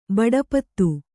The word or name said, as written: ಬಡಪತ್ತು